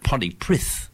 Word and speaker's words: Pontypridd: And I called it Pontypridd, but a Welshman corrected me and said, no, it's pronounced Pontypridd. But you can look at the Welsh language written down, yes. And when they say it, it's nothing like what's written down.